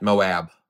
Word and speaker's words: Moab: So on 101 Places to Party Before You Die, you guys go to Maui, Moab? How do I say that? – Moab. – Moab, Miami, Puerto Rico, Portland, Richmond, yeah.